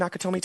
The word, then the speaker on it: Nakatomi: Uh, we're going to be in Nakatomi Tower today. And you know what? That is how you pronounce it. I'm in the right because that's how the module was written.